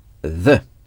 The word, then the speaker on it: the: L'article « la » ou « le » se dit « the ». The. Ça s'écrit T T-H-E. Le TH, ça se prononce the. – The. – The. – The. – Avec la langue entre les dents.